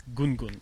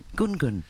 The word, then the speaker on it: Gungun: Okay, but it's not as hard as Gungun, it's Gungun. – Gungun.